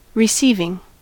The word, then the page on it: receiving: American English